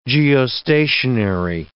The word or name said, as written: geostationary